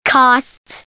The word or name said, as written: costs